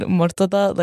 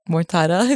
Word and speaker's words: Mortada: And so it's pronounced, yeah, like you said, Mortada, like Mortada.